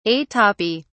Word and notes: atopy: British English

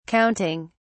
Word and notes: counting: British English

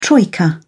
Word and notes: troika: British English

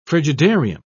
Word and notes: frigidarium: British English

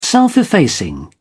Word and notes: self-effacing: British English

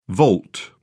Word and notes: volt: British English

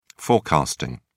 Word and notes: forecasting: British English

